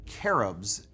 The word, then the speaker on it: cherubs: However, cherubs, or cherubs, as it would be more properly pronounced, are nothing like these depictions at all.